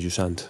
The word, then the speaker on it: Jusant: Lunes, ya sabéis que no hay lanzamientos, entonces empezamos oficialmente el martes 31, que salen Husant, Jusant, ¿cómo se pronuncia? – Eh, Jusant, dice Per siempre. Jusant. – Jusant.